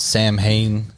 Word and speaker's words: Samhain: Because the holiday itself, I believe, is pronounced Samhain, and this is like the All Hallows Eve.